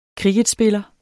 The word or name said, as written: kricketspiller